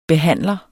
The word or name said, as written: BEHANDLEREN